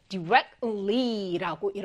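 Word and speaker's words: directly: 이것이 앞에 있는 t가 발음이 좀잘안 들려요. 그래서 우리 친구들이 듣고 쓸때 t를 많이 빠뜨리는 경향이 있거든요. 이거 어떻게 발음한다? direct T는 한번 꽉 잡아주는 거예요. 그래서 directly라고 이렇게 발음되니까 여기 발음 잘 주의해 주시고요.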